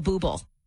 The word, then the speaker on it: Bublé: She's right, Bublé. – It's pronounced Bublé. I know, thanks.